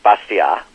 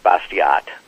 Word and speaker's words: Bastiat: The name's pronounced something in French like Bastiat, mm-hmm. but it looks like Bastiat in terms of English spelling and pronunciation.